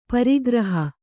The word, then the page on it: ಪ್ರಗ್ರಹ: pragraha